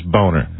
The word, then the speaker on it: Boehner: Spell that name for us, will you? – That's Boehner. – That is boner. – Absolutely boner.